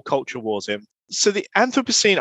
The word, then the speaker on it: Anthropocene: Oh, I think that depends on which side of the pond you are, I think. So I say Anthropocene, but that's just because I'm a Brit.